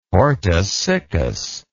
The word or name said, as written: hortus siccus